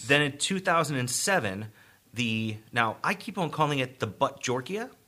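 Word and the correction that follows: Bhut jolokia: So it's not the Buttjorkia. – I don't think it's the Buttjorkia. – But it's Buttjolkia.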